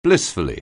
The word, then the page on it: blissfully: anglais